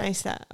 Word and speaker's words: Neistat: How do you pronounce it? – Casey Neistat. Neistat. Neistat. Okay.